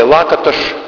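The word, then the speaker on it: Lakatos: Лакаташ сказал, или Лакаташ, если произносить его ближе к тому, как эта фамилия должна звучать по-венгерски, сказал следующее.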